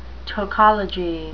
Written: tokology

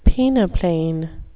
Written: peneplain

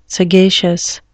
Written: sagacious

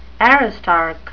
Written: aristarch

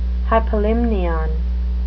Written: hypolimnion

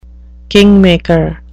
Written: kingmaker

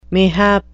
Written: mayhap

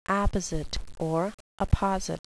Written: apposite

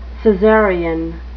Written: cesarean

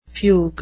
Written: fugue

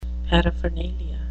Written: paraphernalia

